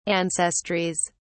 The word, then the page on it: ancestries: British English